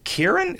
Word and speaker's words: Ciaran: C-I-A-R-A-N. Kieran? Is that Kieran? – Sure.